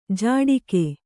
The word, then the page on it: ಝಾಡಿಕೆ: kannada